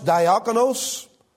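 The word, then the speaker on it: diakonos (διάκονος): The Greek word for deacon is pronounced diakonos.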